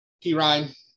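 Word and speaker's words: Perine: And then I can never pronounce it right. But Perrine. – Who is it? Huh? – Perrine. P. Ryan. – Sorry. P. Ryan.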